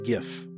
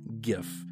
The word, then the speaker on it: GIF: And yes, it's pronounced GIF. I don't care what the creator of the file format said. He is wrong. It's pronounced GIF.